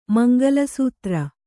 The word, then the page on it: ಮಂಗಲಸೂತ್ರ: mangala sūtra